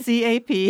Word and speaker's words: ASCAP: ASCAP. – Yeah, ASCAP.